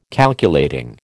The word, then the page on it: Calculating: American English